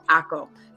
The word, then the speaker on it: AKKO: And the way I remember that, it's really funny, but it's kind of something that Tony taught us is, think of taco, but without the T. So ACO, and that's how I've been able to remember. It's spelled AKKO.